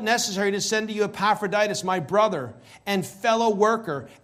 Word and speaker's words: Epaphroditus: Then he talks about Epaphroditus.